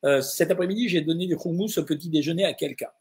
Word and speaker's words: houmous: Alors les gens disent houmous, mais la vraie prononciation à la façon libanaise, c'est houmous.